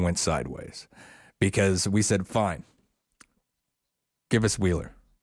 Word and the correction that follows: Whelan: That's how you say it. – Whelan. – Give me Whelan.